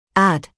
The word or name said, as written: add